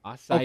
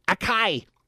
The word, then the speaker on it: acai: Acai. – Okay, yes, I never knew that. It's okay, it's spelled A-C-A-I. I've always been saying Akai.